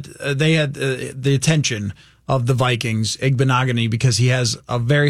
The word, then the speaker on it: Igbinoghene: With the 30th pick in the 2020 NFL draft, the Miami Dolphins select Noah Igbinoghene, defensive back, Auburn. – Well, celebrate for not having to say his name. Igbinoghene.